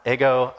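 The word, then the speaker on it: ego (ἐγώ): It's pronounced ego, I, me.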